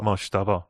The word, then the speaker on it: Mojtaba: Mojtaba. – Mojtaba, o sea, no es Mojtaba, no.